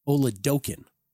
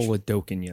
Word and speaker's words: Oladokun: or Chris Oladokun, I'm told is the pronunciation. – Oladokun, yes. – Oladokun, like smoking Chris Oladokun. That's how you remember it.